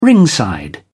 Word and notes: ringside: British English

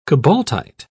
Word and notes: cobaltite: British English

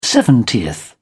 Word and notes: seventieth: British English

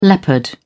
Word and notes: leopard: British English

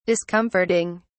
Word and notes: discomforting: British English

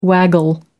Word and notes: waggle: American English

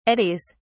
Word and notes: eddies: British English

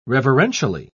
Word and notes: reverentially: British English

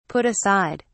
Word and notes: put aside: British English

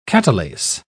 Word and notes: catalase: British English

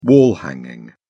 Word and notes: wall hanging: British English

